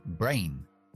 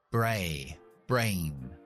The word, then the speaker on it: brain: Es una sílaba, brain. Entonces, yo solo pronuncio la A del abecedario. A, bray, brain, no la I, que sería I, brain, eso no, es bray, brain. O por ejemplo, la palabra carne, que es M-E-A-T, pues solo pronuncio la E, que es I en el abecedario.